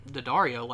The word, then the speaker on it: Daddario: Yeah, Daddario, like the guitar string? – Mm-hmm. – Huh. – Pretty sure that's how it's pronounced.